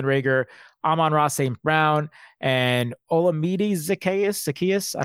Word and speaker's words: Olamide Zaccheaus: Olamide Zaccheaus. – Thank you very much. I appreciate that. Olamide Zaccheaus.